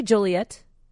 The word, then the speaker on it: Joliet: Yes, I would say Joliet. – Okay. – I'm so happy I said that correctly.